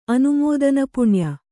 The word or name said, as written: ಅನುಮೋದನಪುಣ್ಯ